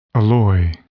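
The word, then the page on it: alloy: verb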